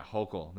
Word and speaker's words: Hochul: Hochul? – Yeah, Hochul. That's how you say it? – I believe so. – I'm saying like Herschel.